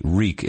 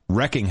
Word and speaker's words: wreak: W-R-E-A-K? – Yeah, Reek. – Reeking is wreaking havoc, I think, is maybe. – But do you pronounce it that way, Reek? – R-E-E-K. – But you pronounce it Reek? – I think so. I don't think that, yeah, you must. – Because the Obama speech, he misread it, and he said, wrecking havoc.